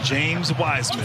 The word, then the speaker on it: James Wiseman: You have Zeke Nagy, and then James Wiseman.